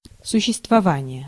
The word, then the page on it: существование: /sʊɕːɪstvɐˈvanɪjɘ/